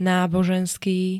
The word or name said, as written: náboženský